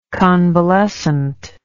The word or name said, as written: convalescently